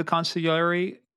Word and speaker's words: consigliere: Now, did the capos report into the consigliere? I almost wanted to say consigliere, but consigliere, is that the pronunciation? – Consigliere, yeah.